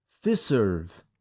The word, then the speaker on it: Fiserv: How do you spell that? – The F-I-S-E-R-V. – Fiserv. Fiserv. Yes. It's fun to say.